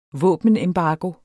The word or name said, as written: våbenembargo